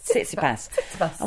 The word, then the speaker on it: Tsitsipas: Somebody told me that you write sits, S-I-T-S, yeah. And then ipas, but you put the T in front, but you just, it's sits, ipas, Tsitsipas.